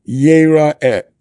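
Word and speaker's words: Yira'eh (יֵרָאֶה): Now, how is it written? It's written as if it says, Yir'eh kol zechurcha. And we're told that it's meant to be pronounced, Yira'eh kol zechurcha.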